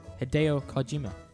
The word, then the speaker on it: Hideo Kojima: That's not how you say it. Hideo Kojima.